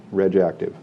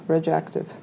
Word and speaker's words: RegActive: It's spelled R-E-G apostrophe A-C-T-I-V, RegActive. – RegActive.